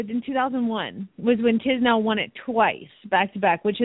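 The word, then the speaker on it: Tiznow: Tisnow? Am I saying it right? – Tisnow. – Yeah.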